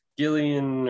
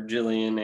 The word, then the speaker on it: Gillian: So yeah, uh, Gillian or Jillian Anderson. Yeah. I feel like she uses Jillian, even though it's spelled the same as like Gillian Jacobs, yeah. you know. – But I think for some reason she does Jillian.